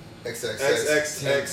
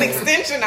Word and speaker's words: XXXTentacion: How do you pronounce it? – XXXTentacion. – So you do say that. – Because I'm like, so it was Extension. I was like, okay. – Extension. – Look, that's me being old.